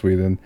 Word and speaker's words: Whedon: Yeah, it's Whedon.